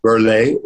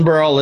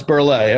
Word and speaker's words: Burrell: Burley is the pronunciation, even though it's spelled Burrell. – So I can't say Milton. It's not Milton Burrell. It's Burley. Okay.